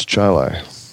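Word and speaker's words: Chili: And then, even though it looks like Chile, is that pronounced Chile? – It's Chile.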